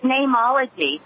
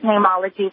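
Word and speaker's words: Neimology: And the Latin word for name is N-E-I-M. And so that's what I did. So I still pronounce it Nameology, but it's spelled the Latin way.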